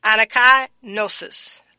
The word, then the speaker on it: anakainosis: And it's called, let me spell it for you. And then I'll pronounce it. And now it's spelled N, excuse me. Let me say that again. A-N-A-K-A-I-N-O-S-I-S. Again, the word is spelled A-N-A-K-A-I-N-O-S-I-S. Anakai gnosis. Anakai gnosis.